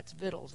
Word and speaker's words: victuals: And I said that right. That's victuals. That's the word.